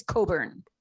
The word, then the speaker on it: Cockburn: It's spelled Bruce Cockburn. – Okay. – Yeah. – Oh. – But it's pronounced Bruce Cockburn.